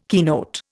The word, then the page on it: keynote: Indian